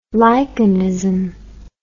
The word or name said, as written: lichenism